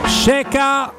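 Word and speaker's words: Xeka: Ceca si scrive Xeca ma noi lo pronunciamo bene perché ce l'ha spiegato Tassotti e Renato Sanchez entrambi del Lille sono loro i due obiettivi di mercato del centrocampo della Roma?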